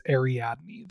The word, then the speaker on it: Ariadne: I've heard it a couple different ways, but Ariadne, the Princess of Spiders. – Yes, and that is actually correct. She did correct us or let us know that it is Ariadne. That is the way it is pronounced.